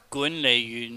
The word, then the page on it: gunleihyuhn: cantonese